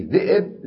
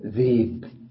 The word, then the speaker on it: ذئب: هذا التسهيل، هذا لغة قريش لا تلفظ الهمز، يسمونه النبر وكانوا يكرهون النبر، فيقولون مثلا في ذئب ذيب ذيب.